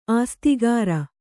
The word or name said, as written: ಆಸ್ತಿಗಾರ